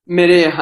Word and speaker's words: Merea: Merea.